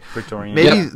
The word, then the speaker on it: Praetorian: In fact, remember we talked about maybe the Praetorian Guards, am I saying that right? – Praetorian.